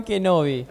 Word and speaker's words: Kenobi: Obi-Wan Kenobi.